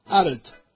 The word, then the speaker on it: Arlt: Entonces, satisfecho de ponerlo en un apuro al pedagogo, le dictaba: Arlt, cargando la voz en la L, Arlt.